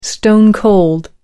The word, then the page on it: stone cold: American English